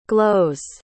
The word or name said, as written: glows